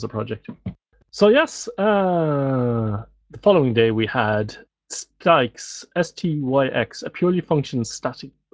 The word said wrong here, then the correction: Styx: Your pronunciation threw me slightly there because I would pronounce it sticks to rhyme with nicks.